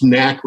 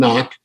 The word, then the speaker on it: NAHC: Uh, little anecdote on that is it is pronounced knack rather than knock. The board decided long ago they didn't want knock knock jokes. So even if it sounds like with the H in it should be knock, it, was really knack.